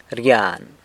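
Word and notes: rgyan: San Lucas Quiaviní Zapotec

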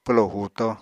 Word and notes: pwlo huuto: Apatani

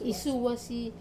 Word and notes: suwasi: Wamesa

